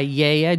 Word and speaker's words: Gaia: N-O-T-I-O-S. – And it's made by Yaya, G-A-I-A. They don't pronounce the G.